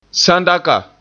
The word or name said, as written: sandaka